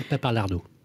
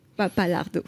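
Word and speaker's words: Pappalardo: on a beaucoup beaucoup Alors quoi de neuf Michel Paplardo, et je m'excuse si j'ai écorché son nom et si elle nous écoute. – Michel Papalardo. – Papalardo.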